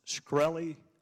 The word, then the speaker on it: Shkreli: Is it pronounced Shkreli? – Yes, sir.